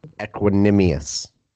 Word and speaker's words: Equanimeous: Did I say that correctly? Equanimeous. Equanimeous? – Equanimeous.